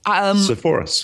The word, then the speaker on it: Sepphoris: You talk about the village of Nazareth and Sepphoris. Is that how it's pronounced? – Right. – Sepphoris? – Sepphoris.